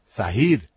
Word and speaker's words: Zahir: Zahir se escribe con Z. Zah. Y en realidad es Zahir, porque hay una H. Z-A-H-I-R.